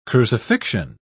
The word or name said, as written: crucifixion